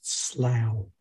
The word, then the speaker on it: slough: I think you find it's pronounced slough.